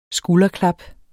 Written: skulderklap

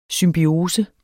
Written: symbiose

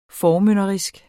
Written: formynderisk

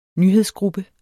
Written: nyhedsgruppe

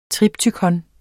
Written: triptykon

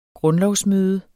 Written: grundlovsmøde

